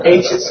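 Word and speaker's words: aegis: How do you pronounce that? Ages, okay. Of Roman indifference.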